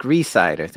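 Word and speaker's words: Rhysider: So this time I have the pleasure to have today with me Jack Reiser. There, is that the correct way to pronounce that? – Jack Reesider, thank you. – Reesider, sorry for that.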